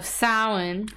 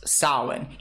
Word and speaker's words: Samhain: He talks about, again, we go back to the pagan holiday of Samhain.